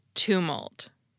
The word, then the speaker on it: tumult: So I'm sorry for the error, and now you too can remember that it's pronounced tumult.